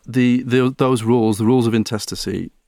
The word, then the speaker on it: intestacy: Now, these are called the rules of intestacy, a great word, which I always have to double check how to pronounce that one. It's always a word that trips me up. When you see it written down, you're like, how do you pronounce that? But it is intestacy.